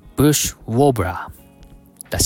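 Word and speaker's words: bush warbler: ブッシュは短く、ブッシュ、ウォーブラーはウォーと伸ばし気味に発音して、その後にブラーと続ける感じ。あまり固くならず自然な流れで言うといい感じだよ。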